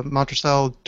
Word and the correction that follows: Monastrell: How do you say it? – Monastrell. – Monastrell. I knew I was saying that wrong.